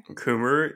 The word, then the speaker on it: Coomer: Is that how you pronounce it? Coomer? – Coomer? Yeah. You've never heard Coomer? – No.